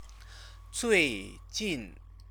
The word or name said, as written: zuijin